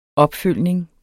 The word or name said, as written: opfølgning